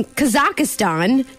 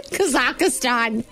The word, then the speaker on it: Kazakhstan: Six tourists in Kazakhstan. Did I say that right? – Kazakhstan.